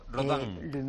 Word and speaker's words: Rodin: Museo Rodin. Bueno, Daniel sabe francés, es como se pronuncia en francés.